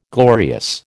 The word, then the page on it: glorious: American English